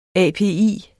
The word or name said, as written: API